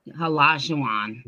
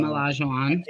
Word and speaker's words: Olajuwon: How you say his last name? – Olajuwon. Yeah. – Yeah. – So who we got? So I got Scotty, Tony Allen, Hakeem Olajuwon. Oh, I said it right.